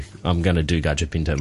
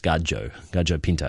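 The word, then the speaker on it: gallo pinto: If you're going to search for it, it's actually spelt gallo, so G-O-L-L-O, but it's pronounced gajo pinto, and then P-I-N-T-O, pinto meaning beans in Spanish.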